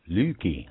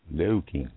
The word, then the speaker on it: Louky: Yeah, it's spelled L-O-U-K-Y. – Lukey. Oh. Lukey. Lukey. Well, see, it's Loki and Lukey in the same word.